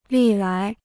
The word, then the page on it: 历来: lì lái